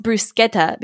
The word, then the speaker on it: bruschetta: It's pronounced bruschetta because it's like an Italian thing. It's not a French thing, but in French it would be bruschetta.